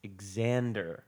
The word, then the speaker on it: Xander: I gave it a Z, and he corrected me to the X. – It's Xander. – Yeah, it's Xander, please. – Don't pronounce the X.